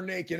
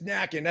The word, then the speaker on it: Nakken: I kept calling her Nakken. It's Nakken. FP Santangelo, thank you for letting me know that I was pronouncing it wrong.